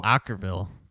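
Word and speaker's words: Okkervil: All right, this next one's Andy's last song, Unless It's Kicks, Okkerville River. Did I say that right? – Okkerville by The Stage Name, yeah.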